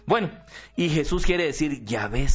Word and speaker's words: Yahvé: Y ustedes saben que en hebreo, los hebreos, el alfabeto hebreo no tiene vocales, son solo consonantes, J, W, H, W, y se pronuncia científicamente Yahvé. Si ustedes me escuchan decir, mi house is beautiful, ustedes se van a reír y van a decir, mire ese cura ahí haciéndoselas, ¿sí? Y no, es my house is beautiful. Lo mismo, J, W, H, W es Yahvé, científicamente, no Jehová. Jehová está mal pronunciado.